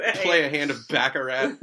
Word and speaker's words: baccarat: It's baccarat! – Baccarat!